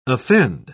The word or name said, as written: offend